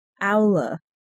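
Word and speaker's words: Aulë: It's pronounced Aula.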